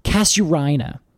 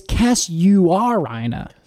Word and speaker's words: Casuarina: Now, I have always pronounced this Casuarina, but apparently it's Casuarina. – Casuarina? – Yes. So it's like C-A-S-U-A-R-I-N-A. So when I read that, I kind of just jam the U-A-R all together.